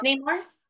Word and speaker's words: Neymar: And then number six is another soccer player at 95 million, which, excuse me if I pronounce this wrong, which is Neymar. I think that's how you say it. He's for Brazil. – Yes. So, Neymar. – Yeah, Neymar. N-E-Y-M-A-R.